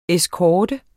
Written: eskorte

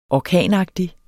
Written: orkanagtig

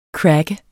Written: cracker

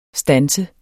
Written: STANSER

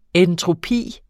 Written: entropi

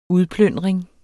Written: udplyndring

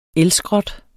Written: elskrot

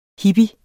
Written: hippie

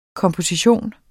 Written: komposition

